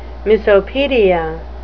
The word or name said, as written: misopedia